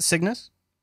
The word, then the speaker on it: Cygnus: So Cygnus, how is that how you say it? Cygnus? – Yeah.